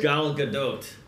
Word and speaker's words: Gal Gadot: Gal Gadot. Yeah, dude, I pulled up a video. That's how she pronounces her fucking name. – No, I'm proud of you for pronouncing her name right.